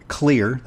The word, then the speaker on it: Xlear: So let's remind our listeners where CLEAR, that's spelled X-L-E-A-R, paradoxically, it's X-L-E-A-R, nasal and sinus and spry dental products from CLEAR, Inc.